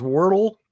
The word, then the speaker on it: Wordle: So if you want to play Wordly, you have to go to the website. – It's called, I think it's pronounced Wordle. – But yeah, well, yeah, correct. – But, Wordle is correct.